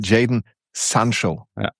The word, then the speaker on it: Jadon Sancho: Der hat in diesen Videos, die dann für die Bundesliga gemacht werden, hat er halt immer klar gesagt, ich heiße Jadon Sancho. – Ja, trotzdem wird er halt ganz auf Jadon Sancho genannt, weil es halt auch so diese amerikanische Aussprache dann eher ist, die ja auch einige von uns gewohnt sind.